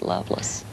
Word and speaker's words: Lovelace: I married a guy from North Carolina who, his name is spelled Lovelace, but they pronounced it Loveless.